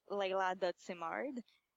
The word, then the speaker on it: Simard: It's leila.simard. S-I-M-A-R-D.